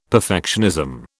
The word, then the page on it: perfectionism: British English